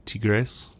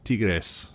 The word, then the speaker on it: Tigres: To more than anything, like there's an early thing to say, hey, look, I mean, even if it doesn't go well against Tigres, not Tigre, everyone, pronounce not that S. – Tigres, it's Spanish.